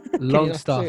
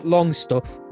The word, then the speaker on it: Longstaff: Longstaff. – Yeah. Not Longstuff. Longstaff.